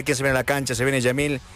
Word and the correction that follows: Lamine Yamal: Lamine Yamal, ¿no?